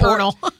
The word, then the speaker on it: pernil: Yeah, that's I can't say it like that. Yep, it's pernil.